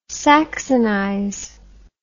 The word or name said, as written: saxonize